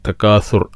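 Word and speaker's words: تكاثر: അൽഹാക്കുമു തക്കാസുർ, തക്കാസുർ അസ അലിഫ് ബ ത ഥ അക്ഷരാണ്. അപ്പം ശരിക്ക് ഉച്ചാരണം ശ്രദ്ധിക്കുക. തക്കാസുർ സ എന്ന് ഉച്ചരിക്കരുത്.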